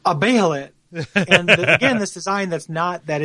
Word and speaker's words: Behelit: Am I pronouncing that right? I say like Behelit. Behelit? – Okay. They say Behelit on both the animated forms I've seen. – Okay, so I will defer to that.